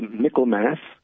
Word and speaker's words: Michaelmas: Is that how you pronounce it, or how do you pronounce it? – Yes, that's what they say. They say Michaelmas. – Mm-hmm. – Like there is, you know, Christmas, and that's the Christ Mass.